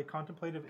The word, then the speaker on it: contemplative: By the way, contemplative is four syllables.